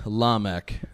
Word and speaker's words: Lamech: Or how do you say his name? – Lamech.